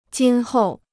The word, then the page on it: 今后: jīn hòu